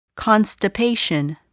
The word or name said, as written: constipation